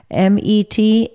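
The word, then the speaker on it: Metanoeo (μετανοέω): The New Testament word for the act of repenting, metanoō. I can't even pronounce it properly. Metanoeo.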